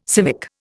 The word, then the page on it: civic: Indian